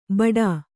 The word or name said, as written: ಬಡಾ